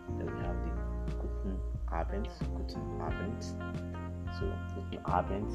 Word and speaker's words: Abend: Guten Abend. So the D is not pronounced like D. Rather, it's pronounced like T.